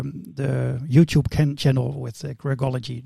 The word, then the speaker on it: Quirkology: we, we, will meet each other, but uh, it's Quirkology. Do I pronounce it yeah. well? Quirkology. – Yeah, very good.